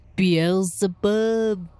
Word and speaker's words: Beelzebub: Well, that and they had found out the name of Beelzebub.